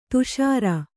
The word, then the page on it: ಧಸರಿ: dhasari'